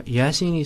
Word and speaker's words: Yaseen: Yeah, Yaseen is spelled Yaseen.